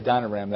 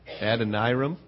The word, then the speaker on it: Adoniram: I always say Adoniram. That's not how you say it. How you say it? – Adoniram.